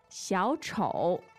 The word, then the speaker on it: 小丑: 小丑 The tones are? – Two third tones. – Mm. Third and third.